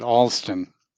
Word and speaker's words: Alston: Let me correct you one thing. We pronounce it Alston.